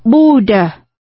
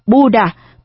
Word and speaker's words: Buddha: Chữ Buddha, Phật Đà, nghe như là chữ bất đại, theo tiếng quan thoại, bất đại phát âm là Buddha, không to lớn.